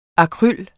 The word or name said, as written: AKRYLET